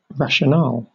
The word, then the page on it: rationale: British English